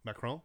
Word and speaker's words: Macron: I think it's Macron. – Care. Macron? – Yeah.